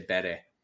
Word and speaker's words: Bury: The reason I didn't say it is because I don't know if I say it right, because I say Bury where I'm from, but other people say Bury.